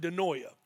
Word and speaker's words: dianoia: That's how it's said, dianoia.